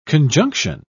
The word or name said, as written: conjunction